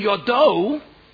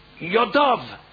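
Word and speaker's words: Yodov (ידיו): It's not, it's written Yodo, it's written his hand, but it is supposed to be pronounced Yodov, his hands,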